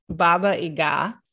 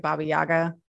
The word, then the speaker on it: Baba Yaga: But I was told by my ex-sister-in-law, who is Czech, that it's Baba Yaga. But I have also heard it Baba Yaga, so who knows? – Maybe it's like Iaga.